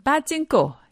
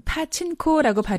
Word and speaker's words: 파친코: 북한에서는 빠찐코, 영어로는 파친코라고 발음하는 이 게임이 한반도 일제강점기 시대에 일본 땅에 건너간 제일 한인들의 역사 속에 등장했다는 것 알고 계신가요?